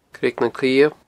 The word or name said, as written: Creag nan Cliabh